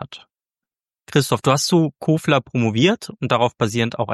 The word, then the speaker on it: Kofler: Kofler, das ist mal, da komme ich immer durcheinander. Kofler wird da ausgesprochen. – Ja, österreichisch.